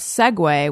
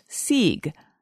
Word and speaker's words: segue: But until I heard someone read the word aloud, I initially thought segue was pronounced Sieg.